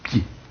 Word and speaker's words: pied: Remarquez, prenons un mot tout à fait simple, tout à fait vulgaire, le mot pied. Non, rien de plus simple que cela. Qu'est-ce qu'il y a dans la prononciation? Dans une prononciation correcte, il y a un peu, un iode et un nez fermé.